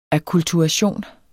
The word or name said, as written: akkulturation